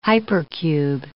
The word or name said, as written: hypercube